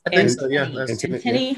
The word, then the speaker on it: antennae: And then the favorite thing that I have read in the last month or two is Zigzag Claiborne's Afro Puffs Are the Antennae of the Universe. Am I saying that right? It's antennae. – Yeah. I always mispronounce that word.